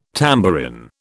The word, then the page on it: tambourin: British English